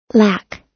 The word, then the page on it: lack: American English